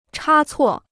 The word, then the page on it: 差错: chā cuò